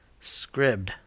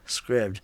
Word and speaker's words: Scribd: Scribd. – Scribd.